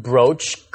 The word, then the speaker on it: brooch: Can I say a certain brooch caught my fancy? – Sure. – Then you're using it as a noun. – Well, thanks for explaining all that. – No problem. – 首先注意这个字的发音 B-R-O-O-C-H 读作 brooch brooch 就是胸针还有一个 brooch 要注意 B-R-O-A-C-H